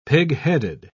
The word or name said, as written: pigheaded